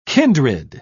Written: kindred

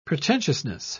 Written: pretentiousness

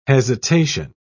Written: hesitation

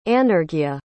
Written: anarghya

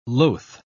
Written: loth

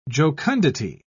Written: jocundity

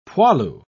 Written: poilu

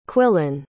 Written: quillan

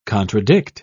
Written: contradict

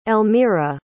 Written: Elmira

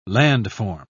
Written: landform

